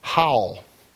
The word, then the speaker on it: Hal: and it was spelled H-A-L, and so you would pronounce that Hal, and you would be wrong. It was not Hal, it was Howl, which is what I said, but I made sure to get it right.